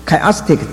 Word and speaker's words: chiastic: 这个交错配列法英文叫做 chiastic， chiastic 意思是希腊文的 x 那个音念成 chi，所以那个 chiastic chia 那个字是希腊文的 x。